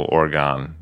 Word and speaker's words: Oregon: Yeah and then there's no Oregon. It's Oregon, yeah, like O R Y G U N, that kind of spelling.